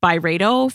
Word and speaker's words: Byredo: And it's actually a candle collab with Ben Gorham himself, who's the founder of Byredo, if you guys are not familiar. And by the way, Carlene, we've been pronouncing it Byredo for how long? It's actually Byredo.